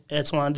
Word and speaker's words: Antwan: And I am on Instagram also, _ Antwan Jr., _. Do not spell my name wrong. Please do not spell my name wrong. You will get the wrong one. It is spelled A-N-T-W-A-N.